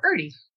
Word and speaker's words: IRTE: But for now, as the founding members of IRTE, do you pronounce it like a soft I, IRTE, or is it like art, like the word art? – No, IRTE. – IRTE. – Rhymes with flirty.